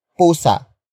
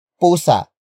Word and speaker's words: pusa: It is the focus marker that introduces the main topic of the sentence, which in this case is pusa. Pusa. Pusa. Pusa is spelled as P-U-S-A.